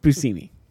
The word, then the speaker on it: Buscemi: Okay, it's pronounced Buscemi. – Is it Buscemi? Not Buscemi? – It's not Buscemi. It's Buscemi.